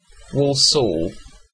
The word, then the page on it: Walsall: British English